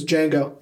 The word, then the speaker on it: Django: And he goes, "Django." – The D is silent.